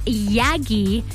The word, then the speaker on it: Yagki: Her name is Yagi. The K is silent. Y-A-G-K-I.